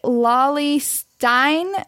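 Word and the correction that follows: Cauley-Stein: will get drafted to the Nuggets? I may have butchered his name. – Willie Cauley Stein. – Cauley. There we go.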